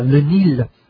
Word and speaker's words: Le Nil: được viết là N-I-L-E, tiếng Pháp là N-I-L mà phải đọc là Le Nil.